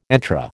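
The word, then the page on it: entrée: American English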